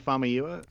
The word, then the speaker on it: Famuyiwa: It's definitely Famuyiwa.